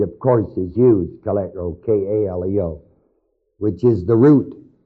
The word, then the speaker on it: kaleo: The root of this word called here in Romans 1, the root is the word kaleo, K-A-L-E-O, long O, according to the Greek text.